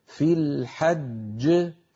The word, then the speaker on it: الحج: هذه الصفحة التي مرت معنا من جملة ما مر قوله تعالى لما وقفنا ولا جدال في الحج وقفنا على جيم مشددة والجيم المشددة تتألف من جيمين الجيم الأولى مدغمة والجيم الثانية كانت متحركة في الوصل فسكنت للوقف فلما سكنت للوقف لا بد من قلقلتها أما الأولى فهي مدغمة لا قلقلة فيها ويكون النطق هكذا ولا جدال في الحج في الحج فننطق جيمين فلينتبه إلى ذلك